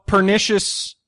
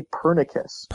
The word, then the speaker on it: Pernicious: So, Pernicious, I'm saying it right? Pernicious. – Yeah, pernicious. Pernicious. Definitely. A lot of people say Pernicus. – Pernicus. – It is pernicious.